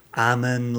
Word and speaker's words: Ammon: And so I've been practicing Ammon like salmon.